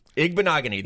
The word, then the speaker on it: Igbinoghene: Igbinoghene. – Igbinoghene. – That's it.